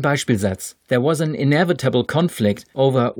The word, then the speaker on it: conflict: Sie kennen das deutsche Wort der Konflikt und damit kennen Sie auch das englische Wort the conflict. Nun ja, die Betonung ist im Englischen auf der ersten Silbe und deshalb dürfen Sie sich die erste Silbe auch wesentlich größer vorstellen als die zweite.